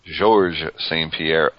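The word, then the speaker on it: Georges St-Pierre: I got to miss the main event, which was George St. Pierre? Is that the proper pronunciation, yes. Or is it Georgia St. Pierre? – George St. Pierre. – George St. Pierre.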